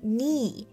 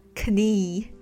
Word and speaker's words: knee: 这里还可以注意 knee 膝盖这个单词，虽然呢拼写是 k n e e，但是呢开头的这个 k 是不发音的，所以就是 knee，不要读成了 knee。